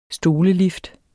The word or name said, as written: stolelift